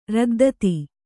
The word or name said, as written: ರದ್ದತಿ